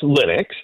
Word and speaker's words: Linux: It's pronounced Linux, okay. And it's actually named after the guy that made it. His name is Linus.